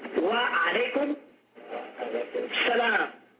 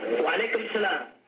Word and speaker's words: Wa alaykum salam: Wa alaykum salam. As-salamu alaykum. Wa alaykum salam.